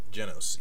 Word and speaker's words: Genos: And Genos, I think is how you pronounce it, right? Genos. – Genos. Yes.